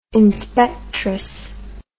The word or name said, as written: inspectress